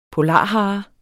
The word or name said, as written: polarhare